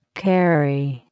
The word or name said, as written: carry